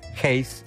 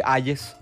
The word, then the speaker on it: Hayes: ¿Sabían que así es la pronunciación del apellido? Hayes, Hayes.